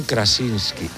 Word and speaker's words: Krasinski: A ver si lo digo bien. John Krasinski. – Krasinski se llama.